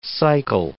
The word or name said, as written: cycle